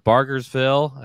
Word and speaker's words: Bargersville: In fact, Chief Eric Funkhauser, who is out of Bargersville. I might have pronounced that wrong. – That's correct.